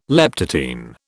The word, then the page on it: leptotene: British English